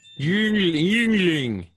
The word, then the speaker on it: Yuengling: It's Yuengling. – Oh, I don't know how to pronounce that. – Yuengling.